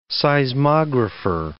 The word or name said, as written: seismographer